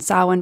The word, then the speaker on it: Samhain: So yes, let's call it Samhain from now on. And the reason why I tripped up at first is because it's spelt Samhain.